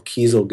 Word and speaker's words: Kiesel: They're called Kiesel Guitars, spelled like the word diesel, but with a K at the beginning.